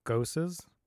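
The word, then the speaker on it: Gose: Yeah, and I mean, the beers they have on tap, they range from double IPAs, Gosses. Did I pronounce that correctly, Richard? – You did.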